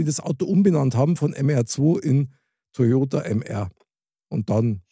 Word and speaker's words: MR2: MR2. Ja, so, aber so wie die Franzosen das aussprechen, sprechen die das aus als Merd.